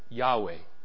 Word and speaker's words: Yahweh: Depending on how you change Hebrew letters into English, that's either J-H-V-H, which we pronounce out loud, Jehovah, or YHWH, which we pronounce out loud, Yahweh.